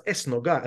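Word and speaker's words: esnoga: And the word Esh Noga, except it was probably pronounced Es Noga, and that's how it's in Spanish, means in medieval Spanish a synagogue.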